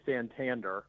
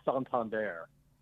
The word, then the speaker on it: Santander: Yes, I still cringe when some announcers say Santander. It is Santander. Much classier. – But that's, But that's fun. That's fun. – Yes, totally. Sounds fun with Santander.